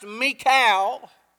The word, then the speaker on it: Michal: And I want to try to preach this morning for a little while on the subject, why, and her name is pronounced Michal. I always say Michal, but it's Michal.